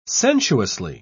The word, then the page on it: sensuously: British English